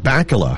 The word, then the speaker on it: Bakula: Up next on our celebrity birthday list, rhymes with Hakala, Scott Bakula has a birthday today.